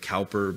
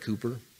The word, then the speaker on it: Cowper: I know it looks like Cowper, but the Brits pronounce that Cooper.